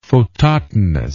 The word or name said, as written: phototonus